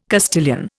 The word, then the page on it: Castilian: Indian